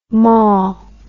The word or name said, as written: mawl